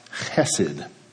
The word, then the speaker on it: chesed: It is the Hebrew word chesed, sometimes written with a ch instead of an h. I will pronounce chesed as chesed moving forward for your sake and for mine.